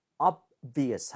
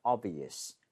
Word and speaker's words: obvious: 너무 세게 내려고 할 필요 없어요. 거의 발음이 안 된다고 봐도 돼요. 왜냐하면 그 바로 뒤에 있는 V가 더 영향을 받으면서 끌고 가요. So, obvious 하지 마시고요. obvious. 이런 식으로 너무 앞에 B 신경 쓰지 말기로.